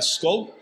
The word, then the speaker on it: skål: Do you know how to say it in Norwegian? Uh, skål. Yes, sir.